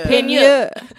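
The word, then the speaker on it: Peigneur: Peignure. – Well, it's a French word, so Peignure.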